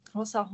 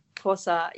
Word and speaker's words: Xhosa: They grew up in a Xhosa home. You know, I can't even pronounce it properly. Sorry, guys. Xhosa.